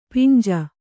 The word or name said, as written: ಪಿಂಜ